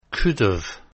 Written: couldve

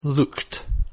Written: looked

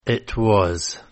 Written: it was

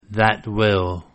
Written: that will

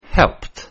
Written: helped

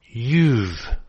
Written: you've

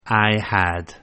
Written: I had

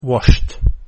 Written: washed

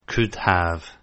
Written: could have

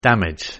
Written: damage

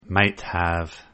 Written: might have

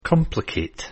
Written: complicate

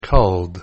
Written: called

